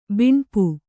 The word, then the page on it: ಬಿಣ್ಪು: tiṇpu